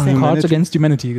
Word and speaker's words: Cards Against Humanity: Ja, Cards Against Humanity, genau.